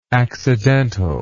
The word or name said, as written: accidentality